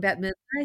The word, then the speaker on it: Bette Midler: young Bette Midler, I think.